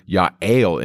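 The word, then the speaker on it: Ya'el (יעל): Now, the word Ya'el in Hebrew means goat. In the English, it's spelled J-A-E-L.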